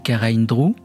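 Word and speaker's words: Karaindrou: Eleni Karahindrou, parce que c'est comme ça qu'il faut prononcer son nom, moi je dis Karahindrou, L'éternité un jour, by the sea, près de la mer.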